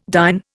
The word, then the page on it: dine: Indian